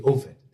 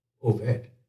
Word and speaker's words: Obed: And the women, her neighbors, gave the child a name, saying, there's a son born to Naomi, and they called his name Obed. Or actually, Obed. The B is pronounced with a V sound, Obed.